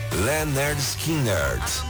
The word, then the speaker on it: Lynyrd Skynyrd: Em seu disco de estreia, lançado em 1973, a banda liderada pelo vocalista Ronnie Van Zandt mostrou ao mundo qual era a pronúncia correta do nome, Lynyrd Skynyrd.